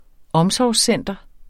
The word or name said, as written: omsorgscenter